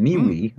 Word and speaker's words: MeWe: MeWe mm.